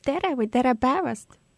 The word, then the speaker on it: Tere päevast: Tere päevast.